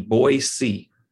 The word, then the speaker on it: Boise: But when you live here, you pronounce it B-O-Y-S-E-E, Boise.